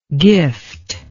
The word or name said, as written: giftless